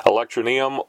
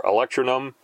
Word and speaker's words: Electroneum: Okay, I've been pronouncing it Electroneum or Electronum. It is spelled E L E C T R O N E U M dot com.